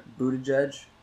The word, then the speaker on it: Buttigieg: Buttigieg. Dude, he's actually really intelligent. I'm just curious. He's a rogue scholar. – I've never heard anybody say his last name. – Buttigieg.